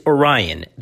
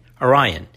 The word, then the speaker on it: Oriahnn: This medication, again, just recently approved at the end of May 2020, is Orion. That's spelled O-R-I-A-H-N-N. Orion.